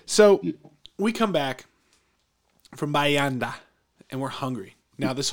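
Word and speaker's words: Bahia Honda: Couple interesting places, Bahi Honda State Park. And we met a young lady who was from New York, then Miami, then the Keys. She said, Bayanda is how you say it. So Bayanda.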